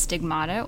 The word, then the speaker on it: stigmata: And I've heard the word stigmata pronounced in that sense, stigmata, or I've heard it pronounced stigmata. Do you have a preference? – I prefer stigmata. – I do agree with you as well. Yeah. Stigmata sounds nice.